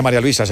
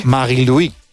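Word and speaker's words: Marie-Louise: No se dice María Luisa, se dice en su... – Marie-Louis.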